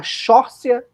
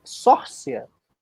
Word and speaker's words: Saoirse: No elenco, a gente tem a Kate Winslet e a Shorcia Ronan. Sabia? Ah, é, a pronúncia do nome dela é Sórcia.